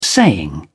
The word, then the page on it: saying: British English